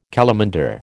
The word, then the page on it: calamander: American English